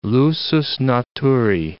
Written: lusus naturae

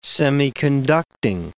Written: semiconducting

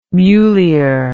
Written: mulier

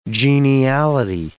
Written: geniality

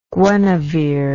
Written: Guenevere

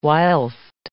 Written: whilst

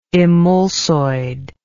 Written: emulsoidal

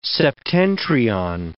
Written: septentrion

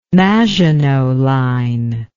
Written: Maginot Line

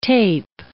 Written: tape

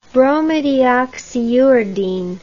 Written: bromodeoxyuridine

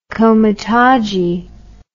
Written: komitadji

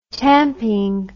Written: tamping

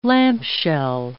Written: lampshell

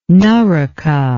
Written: naraka

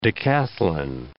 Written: decathlon

